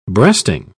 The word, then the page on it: breasting: British English